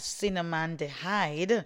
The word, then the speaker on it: cinnamaldehyde: The active compounds like cinnamonaldehyde. Hold on now, let's get this one correct. Okay, I do believe it is pronounced as cinnamaldehyde, help lower low-grade inflammation linked to chronic joint and gut pain.